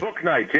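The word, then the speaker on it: Bouknight: They get Bolknight back. How do we say his name, by the way? I want to make sure I get it right. – Bolknight, James Bolknight.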